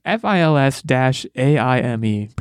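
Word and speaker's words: Fils-Aimé: It's Fils-Aime.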